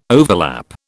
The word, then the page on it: overlap: British English